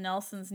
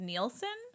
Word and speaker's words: Nelson: Number one, we kept pronouncing Russell M. Nelson's name as Nielsen. Apparently, so yeah, sorry. – I apologize. Nelson.